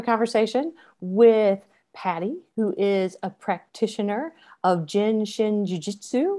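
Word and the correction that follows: Jin Shin Jyutsu: And you are almost right. It's Jin Shin Jitsu.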